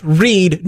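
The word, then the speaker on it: Reade: I believe it is pronounced Reed, no E.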